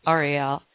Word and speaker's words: Uriel: It's spelled U-R-I-E-L, but it's pronounced Ariel.